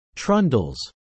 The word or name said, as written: trundles